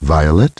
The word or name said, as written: Violet